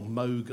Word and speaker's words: Moog: And he bought he tried to get a Moog synthesizer from Rob, or Moog, I think it might be more correctly pronounced, from Moog.